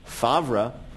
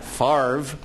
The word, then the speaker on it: Favre: The man who confounded opposing teams' defensive lines by spelling his name as if it pronounced Favre, but pronouncing it Favre.